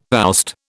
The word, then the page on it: oust: British English